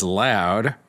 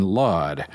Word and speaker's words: Laud: Phil from Pennsylvania sent me a note that I have incorrectly been pronouncing William Laud as Loud rather than Laud. This was especially egregious on my part insofar as the Archbishop's name is spelled L-A-U-D, as in laudable.